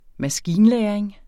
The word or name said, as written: maskinlæring